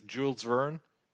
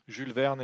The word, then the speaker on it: Jules Verne: So today it's dramatically different from what I read back then, but very, at a very early age, uh, the U, the U.S. pronunciation is Jules Verne, Jules Verne in French.